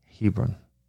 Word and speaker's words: Hebron: Caleb got Hebron. And that's, actually, you said Hebron, I think. That's right. Your Jewish pronunciation is very good. I say Hebron is almost.